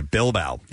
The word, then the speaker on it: Bilbao: Bilbao, Spain. – That was coming. – I guess that's how you pronounce it. – It is, yeah. – Okay, Bilbao.